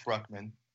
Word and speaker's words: Brukhman: And as I said, I'm here today, blessed, joined by Jake Bruckman.